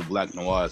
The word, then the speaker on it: Black Noir: I did do my research, though, on, I'm not sure if I'm saying his name right, Black Noir. Yes.